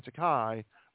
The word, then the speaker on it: Takei: In addition to making a dig at Shatner for drinking too much, Takei also joked about Shatner's rumored toupee, noting that toupee and Takei rhyme, if you pronounce them correctly.